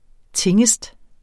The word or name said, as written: tingest